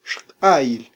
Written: xq'ayil

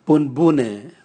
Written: bunbune

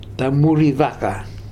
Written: Tamurivaka

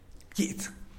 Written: pkiits

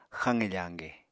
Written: xaqe fañge

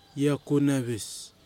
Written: Iakunwis

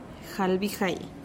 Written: jalbijai

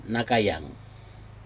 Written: nakayag

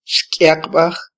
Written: xkyaqbaj

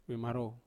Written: vemarou

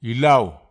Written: ilau

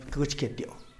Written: pkïtxketioo

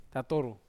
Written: tatoru

